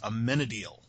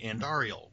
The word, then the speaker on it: Amenadiel: So, for instance, one of the listeners this week let us know that I've been mispronouncing completely the angel friend in Lucifer. His name is Amenadiel. And I have been saying Andariel, which it turns out that Andariel is one of the lesser evils from the Diablo series of games.